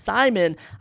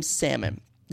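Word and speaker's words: Saimon: S-A-I-M-O-N. I found it was actually pronounced Simon. I've been calling him Salmon this entire time, which I actually think is hilarious.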